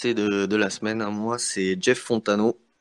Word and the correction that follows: Fontenot: Fontenot, pardon, excusez-moi pour euh, la prononciation.